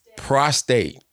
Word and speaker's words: prostate: Prostrate. – Say it, Jamie, how you say it? Prostrate. That ain't when you lay out and pray. That's that. Prostate.